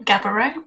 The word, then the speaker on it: Gaborone: Yep, Gaborone.